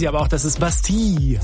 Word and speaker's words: Bastille: Yeah, we in our terrible English accents we say Bastille, even though it's clearly Bastille.